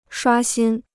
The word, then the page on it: 刷新: shuā xīn